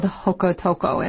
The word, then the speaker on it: Jocotoco: So that's why we hear it called Hocotoco instead of Jocoto or something with a J.